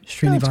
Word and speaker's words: Srinivas: Indian mandolinist Yu Srinivas. I'm not saying that right, am I? Srinivas. – No, close enough.